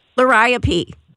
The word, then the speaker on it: Liriope: I planted, and I'm going to probably pronounce this wrong because I've heard it two different ways, Liriope or Liriope. So, how do you pronounce it? – Liriope. Third choice.